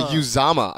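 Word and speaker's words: Uzomah: CJ Uzama, I believe, is the actual proper pronunciation.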